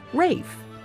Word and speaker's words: Ralph: His first name is spelled R-A-L-P-H, which in America is pronounced the way it looks, Ralph. But in England, for reasons known only to the English, they pronounce Ralph Rafe.